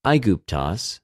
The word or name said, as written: Αἰγυπτος